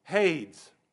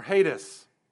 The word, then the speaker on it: Hades: Our English pronunciation, hades or hades.